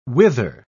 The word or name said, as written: wither